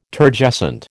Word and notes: Turgescent: American English

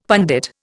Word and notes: pundit: Indian

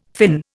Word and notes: fin: Indian

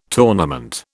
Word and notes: tournament: British English

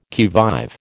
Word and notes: qui vive: American English

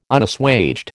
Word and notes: Unassuaged: American English

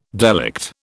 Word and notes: delict: British English

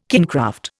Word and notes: kingcraft: Indian

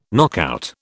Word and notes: knockout: British English

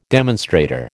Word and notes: demonstrator: American English

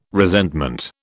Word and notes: resentment: British English

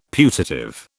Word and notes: putative: British English

